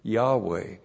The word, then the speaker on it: Yahweh: If you pronounce it, you would pronounce it Yahweh, We bring it across as Jehovah.